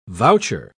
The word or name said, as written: voucher